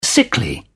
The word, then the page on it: sickly: British English